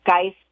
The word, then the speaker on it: Geist: My name is Maura, M-A-U-R-A, Geist, G-E-I-S-T, and it's .com.